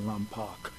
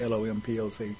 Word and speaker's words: Lompoc: Some people pronounce it Lompoc. – Yes. – It's Lompoc.